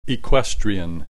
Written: equestrian

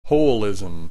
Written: holism

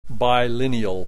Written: bilineal

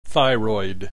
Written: thyroid

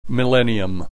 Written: millennium

